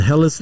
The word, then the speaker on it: Helles: It's a Helles Lager or Hell's Lager, however you want to pronounce it.